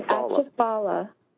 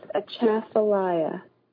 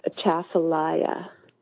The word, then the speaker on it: Atchafalaya: He's saying Atchafala, but it's Atchafalaya. – It's not. – What is that, a place? – The river. – It's an Indian word for the river, name of the river. I pronounce it Atchafalaya, or that's what it's said around.